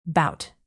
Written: bout

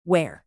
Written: ware

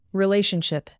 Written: relationship

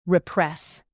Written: repress

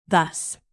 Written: thus

